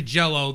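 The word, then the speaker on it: Jellö: Is actually Jello. They just pronounced the umlauted Y as an O.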